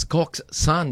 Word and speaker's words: coxswain: So, did you know that Coke Swain pronounces Coke San?